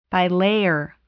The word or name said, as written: bilayer